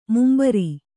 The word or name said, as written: ಮುಂಭಾರ